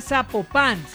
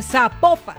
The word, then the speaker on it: Zapopan: Oiga, nos escriben, nos escriben los oyentes y que no se, no se pronuncia Zapopan, sino que se pronuncia Zapopan. – Ah, Zapopan.